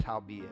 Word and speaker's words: howbeit: I try to make it just all one word, my pronunciation. And my wife said, baby, that's howbeit.